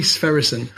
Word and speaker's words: Sverrisson: Skooli Sverrisson. And that is spelled, if you want to check it out, S-V-E...